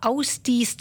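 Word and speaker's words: Ásdís: Ich habe gerade mal geguckt, Asdis schreibt man sie, aber Ausdis spricht man sie aus.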